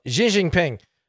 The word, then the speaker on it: Xi Jinping: Xi Jinping. That's how you say it properly.